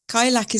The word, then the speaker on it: Cailleach: Cailleach is an Irish word, and there are several pronunciations, but I tend to go with Cailleach.